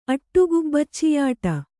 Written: ಅಟ್ಟುಗುಬ್ಬಚ್ಚಿಯಾಟ

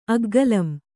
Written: ಅಗ್ಗಲಂ

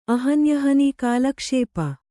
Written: ಕಾಲಕ್ಷೇಪಿ

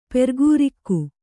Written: ಪೆರ್ಗೂರಿಕ್ಕು

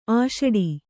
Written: ಅಷ್ಟು

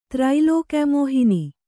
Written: ತ್ರೈಲೋಕ್ಯಮೋಹಿನಿ

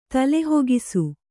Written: ತಲೆಹೋಗಿಸು

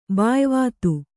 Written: ಬಾಯ್ವಾತು

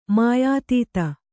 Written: ಮಾಯಾತೀತ

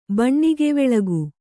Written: ಬಣ್ಣಿಗೆವೆಳಗು

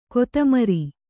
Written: ಕೊತಮರಿ

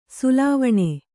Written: ಸೊಲ್ವೆಣ್

